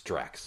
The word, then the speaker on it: Drax: Drax, not Dax. I said Dax. It's Drax, sorry. – How do you pronounce it? – Drax. D-A-R-X.